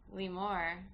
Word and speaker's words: Leemore: So without further ado, I'd like to welcome our guest, Leemore, to the podcast.